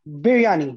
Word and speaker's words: biryani: Biryani, B-I-R-Y-A-N-I, biryani.